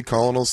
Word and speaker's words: Colonel: How do you get Colonel out of that? Especially when the word Colonel, like a kernel of corn, already exists.